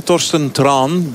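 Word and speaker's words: Torstein Træen: Ik geef toch nog eens één vraag over onze nieuwe leider, hè, Thorsten Traan. Blijkbaar moet je het zo uitspreken.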